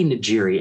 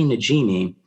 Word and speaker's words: Najimy: And one other actor that I really want to mention is Kathy Najimy. And I mispronounced her name. Kathy Najimy.